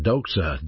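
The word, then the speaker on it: doxa (δόξα): The word glory is a New Testament noun in the Greek New Testament pronounced doxa, D-O-X-A.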